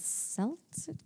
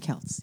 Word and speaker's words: Celts: But if the roots of it are, you know, not just in the Celts? I don't know. – Celts. – Thank you. I never say that right. Because it's the Celtics, but the Celtics.